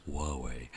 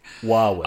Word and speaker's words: Huawei: They're not to sound too technical, but it's actually pronounced Huawei. – Huawei.